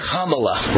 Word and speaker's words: Kamala: think of Kamala, right? The word comma, like a comma in a sentence, comma, period.